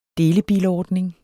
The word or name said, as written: delebilordning